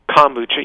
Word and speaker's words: kombucha: Some people, I know I've tried, is it kombucha? Is that how you say it? – Kombucha, yes.